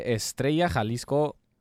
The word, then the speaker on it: Estrella Jalisco: Se dice Estrella Jalisco.